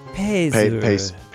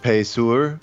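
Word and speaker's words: Payseur: Paz- P A Y S E U R. I have no idea how that would be pronounced. – Pezur. – Pezur.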